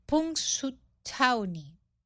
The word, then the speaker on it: Punxsutawney: Eso sucede en Pensilvania, en una pequeña localidad de Pensilvania que se llama, yo no la puedo pronunciar bien, que eh, se llama Punxsutawney, más o menos así se pronuncia.